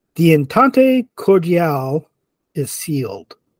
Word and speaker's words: entente cordiale: No, I read it as the entire cordial is sealed. – Oh, yeah, no, you definitely read it wrong. – Yeah, that's why when you said it, I'm like, what? I'm like, No, entente, yeah, I pronounced that correctly, but I don't know if that's just cordial or it's supposed to be cordial or whatever.